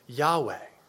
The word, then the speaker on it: Yahweh: And so even now there's this debate about how do you pronounce it? And probably the best way to pronounce it is Yahweh.